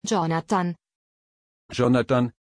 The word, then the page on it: Yonathan: German